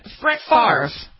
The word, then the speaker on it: Favre: But how does he pronounce it? – Brett Favre. – Favre. Right? – No, F-A-V-R. Favre. Yeah, that's how he says it.